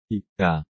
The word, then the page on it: 읽다: イクタ